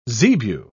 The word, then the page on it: zebu: British English